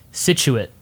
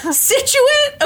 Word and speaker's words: Scituate: S-C-I-T-U-A-T-E. – I have no idea. – Hold, please. – Okay. – Situate. – Okay, Massachusetts. – Come on, Massachusetts. Situate. Okay, Situate.